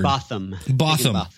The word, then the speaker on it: Higginbotham: who happens to be Stacey Higginbotham. Did I pronounce that right? That's a fun uh, word. Botham. Botham.